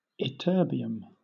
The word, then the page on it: ytterbium: British English